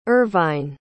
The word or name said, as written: ervine